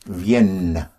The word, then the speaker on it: viennent: And for their coming, you have the E-N-T, but it's spelled V-I-E-N-N-E-N-T, which is pronounced Vienne. – Vienne. – Yes. It so happens that Vienne, their coming, has the same sound as in French, the city of Vienna is called Vienne. – Vienne. – V-I-E-N-N-E.